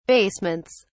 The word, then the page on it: basements: British English